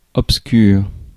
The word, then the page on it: obscur: IPA: /ɔp.skyʁ/; French